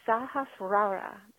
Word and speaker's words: Sahasrara: It's the Sahasrara. That's Sahasrara. The Sahasrara. Rara.